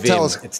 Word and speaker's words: Wim: Oh, well, it's written Wim, but I would assume that it's pronounced Vim. Well, hang on, where's the pronunciation? – Okay, so I'm already suspicious. What's he trying to hide? – It's definitely like, Vim. He's Dutch.